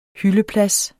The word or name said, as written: hyldeplads